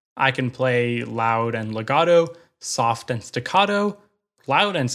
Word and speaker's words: legato: This technique is called legato. In Italian, it means smooth or bound. The way I like to remember it is it rhymes with gelato, the Italian word for ice cream, which is also pretty smooth.